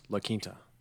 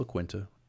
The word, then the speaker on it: La Quinta: I believe it's pronounced La Quinta. – No, it's La Quinta. And La Jolla and Tortilla and all Quesadilla. That. – Yeah, exactly. You know what the fuck the vibes are. – That's true. All right. I know what the fuck the vibes are. – Yes, you're correct. I mispronounced that.